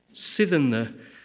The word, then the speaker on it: sithen the: "Sithen the" absolutely insists on being pronounced as a stressed syllable followed by two unstressed syllables, meaning that line one has at least five beats in total.